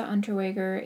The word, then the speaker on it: Unterweger: Jack Unterweger. Hope I'm saying that right. – Seems pretty right. – He was born in 1950 to Teresa Unterweger, a Vietnamese barmaid.